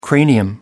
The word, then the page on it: cranium: American English